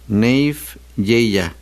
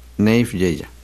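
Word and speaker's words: Naief Yehya: Neif Yeya. Así se escribe. Nayef Y-H-Y-A. Neif Yeya.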